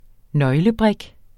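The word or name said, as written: nøglebrik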